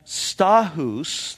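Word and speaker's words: Stachys: Verse 8, he says, greet Amphipolis, my beloved in the Lord, and greet Urbanus, our fellow worker in Christ, and Stachus, that's actually, I looked this one up, how that is pronounced, my beloved.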